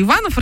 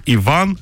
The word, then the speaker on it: Иван: Ладно, все, хотела вам вообще рассказать одну новость про то, что в США очень много Иванов рождается, а в итоге это вылилось. Мы тут еще и кино успели обсудить. – Михаил пишет, америкосы опять все испортят. Будет не Иван, а Иван. С ударением на И.